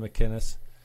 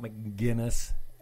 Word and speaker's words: McInnes: Gavin McInnes, not McGinnis. McInnes.